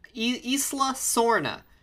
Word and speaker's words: Isla Sorna: 12-year-old Eric Kirby and his mother's friend, Belle Hildebrand, go parasailing near the restricted Isla Sorna. Did I say that right? – Yes.